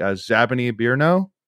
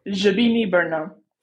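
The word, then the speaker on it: Žabiny Brno: Zabiny Birno. – Zabiny Birno. Okay. – Yeah, the Z's like a J.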